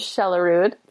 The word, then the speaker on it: Shelerud: My Instagram feed is, you know, not Jenna Kutcher, but mine is Kate, so K-A-T-E. And then my last name is Shelerud, Jenna's maiden name. – you didn't know that. – S-H-E-L-E-R-U-D.